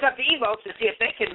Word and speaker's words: Evox: It's spelled E-V-O-X, but it's pronounced Evokes, as is invoking something.